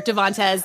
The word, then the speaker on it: Devantez: Did I say that right, Chelsea? – No. but it actually truly does not matter. Devantes.